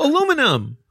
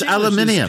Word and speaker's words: aluminium: Aluminum. Yes. – No, it's aluminium.